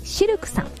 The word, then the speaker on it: CILQ: そして南青山にフレンチレストランシルクさん、 CILQ と書いてシルクさん、